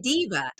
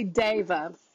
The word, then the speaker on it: deva: I mean, like for years, I've been saying diva. – Yeah, the word is actually deva. Everybody always does, but a diva is spelt with an I normally, and deva is a Sanskrit word, which doesn't mean like the one who proclaims themselves.